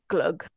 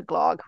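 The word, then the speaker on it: glögg: But it is pronounced in, the Scandinavian way, glug, as opposed to glog, which is what Americans think when they look at it.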